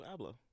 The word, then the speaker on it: Abloh: That's how you pronounce his last name. – No, Virgil Abloh. – Abloh. – Abloh, yeah. – Abloh, yeah. Virgil Abloh.